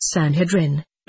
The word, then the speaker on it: Sanhedrin: S, yes, he was taken by the Sanhedrin, pronounced Sanhedrin,